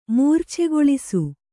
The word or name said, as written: ಮೂರ್ಛೆಗೊಳಿಸು